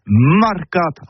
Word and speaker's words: marcat: Mais en Gascogne toulousaine, c'est prononcé les marcat avec un A.